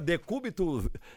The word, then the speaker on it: decúbito: O côncavo e o convexo, o decubo, decubo o quê? Como é que fala? Decúbito, como é que fala? Decúbito, é negócio de... Fátima atrapalhou o negócio todinho aqui, viu? Decúbito dorsal, rapaz, decúbito dorsal.